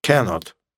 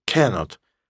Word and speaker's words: cannot: Cannot. Пишется вместе, произносится вместе. С ударением на первом слоге. Cannot.